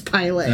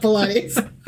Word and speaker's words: Pilate: Is Jesus was brought before Pilate, as in Pontius Pilate. – Okay. – But it was spelled like Pilates. So it's going to be really hard for me to like, no, Pilate, not Pilates.